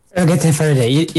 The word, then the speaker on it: Ergete Ferede: Ergete Ferede.